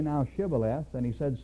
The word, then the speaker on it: Shibboleth: So Jephthah's men would cry out across the river, "Say Shibboleth." And the enemy soldier would say Sibboleth, because he couldn't pronounce it.